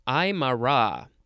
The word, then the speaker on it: Aymara: All right, next we have Aymara. Aymara. There's the, yeah, the emphasis is on the last syllable. Capital A-Y-M-A-R-A.